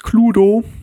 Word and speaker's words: Cluedo: aber das erste bekannte Spiel war Cluedo. Oder, wenn man es richtig aussprechen möchte, übrigens Cluedo.